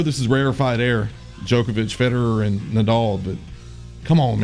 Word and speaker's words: Federer: Of the 14 times he played Federer, he won 10 times. – Federer. Doesn't sound like you're saying it correctly, but that's the way it's said.